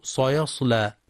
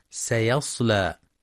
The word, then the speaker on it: سيصلى: ينتبه القارئ لقلقلة الباء في كلمة وتب، كسب، لهب، ويخطئ بعضهم في كلمة سيصلى فيقرأ سيصلى بتفخيم السين، والصواب ترقيقها سيصلى.